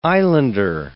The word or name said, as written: islander